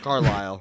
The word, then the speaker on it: Carlisle: Carlisle. – Carl, whatever. Received. Why is there an S's in Carlisle? – It's a silent S.